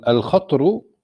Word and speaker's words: الخطر: عفوا شيخنا، الخطر بفتح الطاء وسكونها من الشرف والقدر، ومنها فلان ذو خطر أي ذو شرف ومنزلة، يعني تلفظ بالفتح والتسكين.